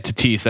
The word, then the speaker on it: Tatis: T- how do you say that? T- I can't say it. – The Tatis. – Yeah, Tatis. That's it.